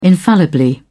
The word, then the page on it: infallibly: British English